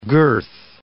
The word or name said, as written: girth